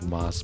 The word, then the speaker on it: Mos: It's spelled like Moss, but he calls him Mos Def.